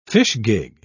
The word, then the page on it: fishgig: British English